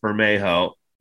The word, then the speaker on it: Bermejo: The reason I know his name is pronounced Bermejo and not like Bermejo or Bermejo is because I went on YouTube.